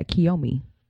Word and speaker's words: Kiyomi: But in some other celebrity news, Bow Wow and his girlfriend, is her name pronounced Kiyomi? – Yeah, Kiyomi.